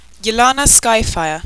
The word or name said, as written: ylana skyfire